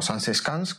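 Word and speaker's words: Zaanse Schans: Sí, y pues este pueblo, Sanseschans, o Sanseschans, como se pronuncia más o menos así, nació gracias a la visión de un arquitecto que se llama Jacques Schipper.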